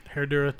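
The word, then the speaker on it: Herradura: Herdador tequila. Am I saying that right? – Dang. – Herdura tequila. – Herdura.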